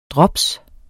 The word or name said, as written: drops